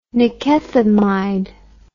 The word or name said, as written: nikethamide